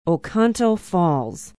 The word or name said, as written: oconto falls